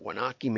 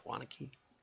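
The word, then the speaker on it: Waunakee: Not everybody would be able to pronounce that, but it's Wanakee, Wisconsin. It looks like Wanakee maybe, but it's not. It's Wanakee. Anyway, and she says there's a lot of things you can do with variable print to improve marketing results.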